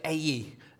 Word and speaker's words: Ai: So I turned to my audio Bible, and David Suchet, the narrator, pronounces it Ai. So I thought, that's good enough for me. They go up to Ai, the Israelites, with its king and its defenses, and something goes wrong.